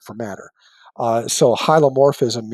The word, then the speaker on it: hylomorphism: But the perspective that Descartes cast aside was that of hylomorphism, which is Slow down. What was it called again? – Oh, sure. Hylomorphism, H-Y-L-O-M-O-R-P-H-I-S-M.